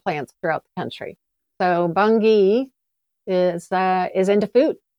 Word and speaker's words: Bungie: It is not. It is Bungie with a hard G.